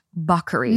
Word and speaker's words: Bacari: B A C A R I. Bacari but it's pronounced Buckery.